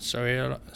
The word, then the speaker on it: Sotomayor: But obviously, RBG and Sotomayor.